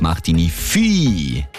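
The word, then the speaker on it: Martigny-Ville: Jetzt dann gerade Achtung, falsch fahrendes Fahrzeug auf der A9 Wöwe Richtung Martigny zwischen Aigle und Martigny Vieh. So spricht man das aus.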